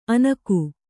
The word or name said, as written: ಅನಕು